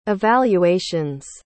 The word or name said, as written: evaluations